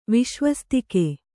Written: ವಿಶ್ವಸ್ತಿಕೆ

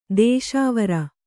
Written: ದೇಶಾವರಿ

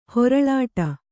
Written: ಹೊರಳಾಡು

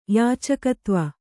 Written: ಯಾಚಕತ್ವ

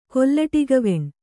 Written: ಕೊಲ್ಲಟಿಗವೆಣ್

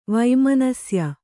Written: ವೈಮನಸ್ಯ

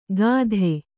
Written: ಗಾಧೆ

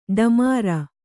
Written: ಢಮಾರ್

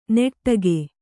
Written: ನೆಟ್ಟಗೆ

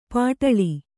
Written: ಪಾಟಾಳಿ

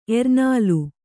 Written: ಎರ್ನಾಲು

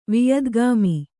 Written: ವಿಯದ್ಗಾಮಿ

